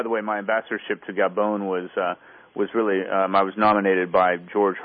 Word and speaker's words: Gabon: Gabon.